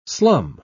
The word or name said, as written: slum